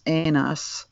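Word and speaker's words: enas (ένας): It comes from one, the numeral one in Greek is enas, ENAS with an accent over the E, and oneness is enotita.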